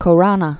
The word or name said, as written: Khorana